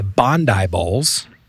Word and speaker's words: Bondi: So I think it would be appropriate for us to hear a little bit about your business, and it's called the Bondi Bowls. – Correct. – Did I pronounce it right? – Yes.